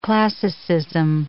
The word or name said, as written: classicism